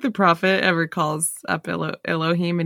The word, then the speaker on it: Elohim: So how do you say it? Elohim? – Yeah, Elohim. – Elohim. Fucking goddamn.